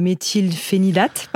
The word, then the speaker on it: méthylphénidate: Qu'est-ce qui est donné en première intention, qui fonctionne plutôt bien, notamment cette molécule que je n'arrive pas à prononcer, qui est le méthylphénidate, c'est ça ? – Exactement, exactement. Prononciation parfaite, c'est le médicament qu'on utilise principalement.